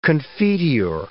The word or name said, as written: confiteor